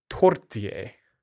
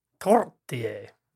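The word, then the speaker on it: tourtière: It's tortier. – Tortier. – So you roll the R, or you don't have to, I guess, but apparently anything is better than what I said.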